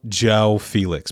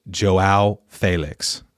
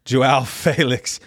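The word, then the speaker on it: João Félix: It's not Joe Felix, by the way. It's Joao Felix. So, my fault. Joao Felix.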